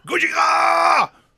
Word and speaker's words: Gojira: Comment il prononce Godzilla? – Gojira? – Gojira! Et c'est pour ça.